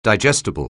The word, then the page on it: digestible: anglais